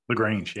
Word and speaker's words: LaGrange: He's a graduate of the University of Louisville and lives in, how do you say that? LaGrange? – LaGrange, yeah.